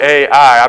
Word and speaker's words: Ai: Am I saying that right, Hopkins? It's literally Ai. I think that's the only way you can say it.